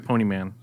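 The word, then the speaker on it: Ponemon: A recent report from the how do you say that? Poneman? Panam? – Ponyman. – Ponyman, or whatever.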